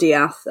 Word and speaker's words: De'Ath: So the Deeth name, not quite spelled like that, but DE'ATH, is a very, very old name that came to England alongside William the Conqueror and the Norman invasion. – Oh, okay. – And it was pronounced Diath, and apparently it was a very much an uppercross name, because I guess it came from the French.